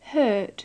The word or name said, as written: hurt